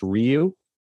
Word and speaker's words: Ryu: funny because it apparently canonically is pronounced Ryu.